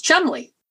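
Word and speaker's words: Cholmondeley: It's like the name Chamondeley, which is pronounced Chumley.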